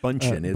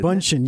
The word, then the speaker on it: Bündchen: No, Bündchen. Uh, Bündchen. It? – Yes, that's that's right.